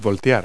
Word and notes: dump: verb

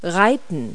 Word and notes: ride: verb

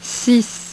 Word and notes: six: French

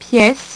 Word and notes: piece: French

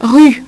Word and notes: rue: French

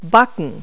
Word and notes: bake: verb

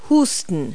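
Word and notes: cough: verb

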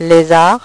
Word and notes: les arts: French

